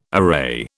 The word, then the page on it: array: British English